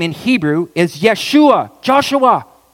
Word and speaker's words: Yeshua: What's significant is that Joshua, okay, you know the Hebrew pronunciation for the word for the name Joshua? Yeshua. Yeshua. Joshua. Yeshua.